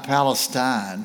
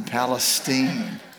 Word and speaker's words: Palestine: And I went out yesterday to Lake Palestine, not Palestine, Palestine. And so I now know the correct pronunciation of that word.